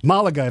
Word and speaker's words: Malaga: Or Malaga. – Malaga, if you say it right.